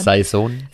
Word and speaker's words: Saison: Wie sagt man das Wort? Das ist also ein Wort, was ich nicht aussprechen kann. – Saison. – Saison, Saison. – Wie sein Sohn. Saison.